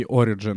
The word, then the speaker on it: Origin: Ну, мені здається, що правильно все ж таки Origin. Ну, запозичене слово, запозичена назва, якщо можна так сказати, тому здається, в англійській мові Origin правильно наголос звучить.